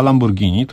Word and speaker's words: Lamborghini: Lamborghini, to się chyba tak wymawia. – Tak, bo to jest włoskie auto i po włosku się wymawia, a nie po angielsku.